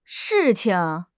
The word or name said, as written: shi4 qing5